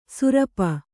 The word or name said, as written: ಸೂರ್ಪ